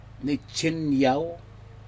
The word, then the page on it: nijhen yaou: Aneityum